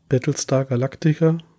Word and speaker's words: Battlestar Galactica: Und habe dann auch Battlestar Galactica, so wird das ausgesprochen, gefunden.